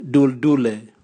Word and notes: duldule: Raga

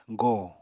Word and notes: gòo: apatani

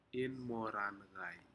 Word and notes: inmorancai: Aneityum

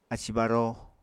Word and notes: ací-baró: Apatani

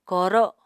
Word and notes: ko7ro7: Apatani

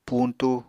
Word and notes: puñtu: apatani

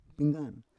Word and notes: penggar: Roon

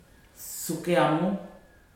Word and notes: sukeamu: futuna aniwa

